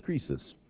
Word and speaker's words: krisis: The Greek word there for judgment or condemnation in Greek is krisis. We have it in English as well. We brought it over bodily, except we pronounce it crisis.